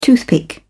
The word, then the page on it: toothpick: British English